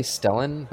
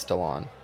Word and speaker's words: Stellan Skarsgård: I always say Stellan, but I think it's Stallone. – The American voice me just says Stellan. Stellan Skarsgård, but I know nothing of how it should be pronounced properly.